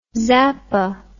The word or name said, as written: zappa